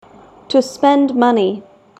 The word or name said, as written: to spend money